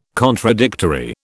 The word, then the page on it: contradictory: British English